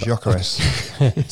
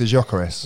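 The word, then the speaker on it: Gyökeres: That was Jokeres. – Sorry, say that. – Victor Jokeres.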